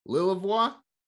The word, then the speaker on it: Lilavois: Mr. Newton Lillevoix.